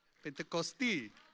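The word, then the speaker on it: Πεντηκοστή: It's from a Greek word, Pentecoste. Close? Rubbish? Pentecosti. Pentecosti. Pentecosti. Ευχαριστώ. Ευχαριστώ. That's how you say it, eh? That's right.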